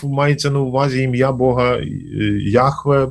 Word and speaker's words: Яхве: Да, єврейською мовою це звучить як Яхве.